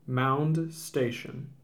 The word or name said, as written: Mound Station